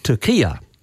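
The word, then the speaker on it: Türkiye: The new spelling has Turkey with an umlaut over the U, and then it goes R-K-I-Y-E, and this new spelling is pronounced Turkia. Now, I've actually heard Penny Wong and I've heard Anthony Albanese say Turkia, and I presume they're doing it for diplomatic reasons to be nice to them, etc.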